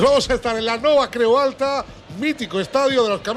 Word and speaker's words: Nova Creu Alta: debut en casa en la nova Creu Alta, lo he dicho bien, ¿no? – Sí, no va, creo, alta.